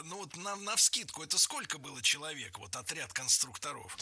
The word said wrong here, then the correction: конструкторов: Конструктора, конструкторов, это неверно. В слове «конструктор» ударение должно сохраняться на втором слоге, на «у». Поэтому конструкторы, конструкторов.